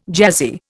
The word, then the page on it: jazzy: Indian